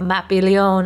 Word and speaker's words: Mapi León: Mapi León at center back.